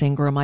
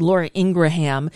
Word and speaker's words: Ingraham: And, you know, Laura Ingraham, I know it's Ingram, I just mispronounce her name on purpose because I can, because I'm pronouncing it as it's spelled. Laura Ingraham was saying, oh, he wins an acting award.